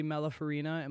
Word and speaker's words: Malafarina: We're here with one of the Drag the Lake hosts, Andy Malafarina.